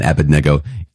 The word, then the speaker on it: Abednego: I always go back and forth with Abednego and Abednego. It's Abednego, I hear, because I believe the accent is on the third syllable.